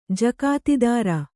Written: ಜಕಾತಿದಾರ

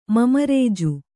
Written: ಮಮರೇಜು

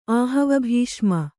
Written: ಆಹವಭೀಷ್ಮ

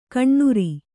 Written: ಕಣ್ಣುರಿ

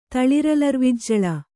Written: ತಳಿರಲರ್ವಿಜ್ಜಳ

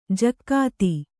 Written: ಜಕ್ಕಾತಿ

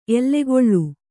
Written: ಎಲುಗುಳಿ

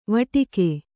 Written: ವಟಕ

